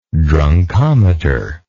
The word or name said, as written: drunkometer